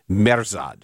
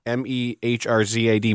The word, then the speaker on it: Mehrzad: And he's known by the first two syllables of his last name, Merzad. Did I say that right? – Yeah. – Mehrzad, Merzad.